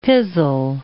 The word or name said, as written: pizzle